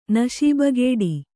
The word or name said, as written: ನಶೀಬಗೇಡಿ